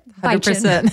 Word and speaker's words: Bitchin': That is exactly how you say it. – Bitchin'. 100%.